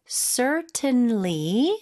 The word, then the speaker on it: certainly: Certainly, 혹은 이렇게 발음하는 분들도 있어요. Certainly, 오. – certainly. – 지금 앞쪽에 강세 주니까 T 사운드가 거의 안 나네요.